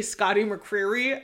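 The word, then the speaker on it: Scotty McCreery: It's like Scotty McCreary. – Oh. – I have a really hard time. I work in radio, in country radio, and whenever I have to say Scotty McCreary, I feel like there's something stuck in my mouth.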